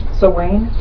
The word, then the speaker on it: Samhain: Samhain. – Samhain, but it's spelled S-A-M-H-A-I-N. I've had a block about remembering how to pronounce it correctly. – Turn the M upside down.